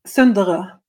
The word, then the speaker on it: Sunderø: Sunderø.